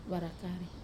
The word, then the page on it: vara kare: Wamesa